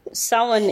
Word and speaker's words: Samhain: Sowan is of course the correct pronunciation of Samhain, yes.